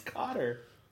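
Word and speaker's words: Kotter: Because he was on that show, Welcome Back, Kata. – Right. – Kata? Mr. Kata. I don't know. – It's Cotter. – Look, Joey from Friends pronounces it Kata, so that's how I'm going to pronounce it.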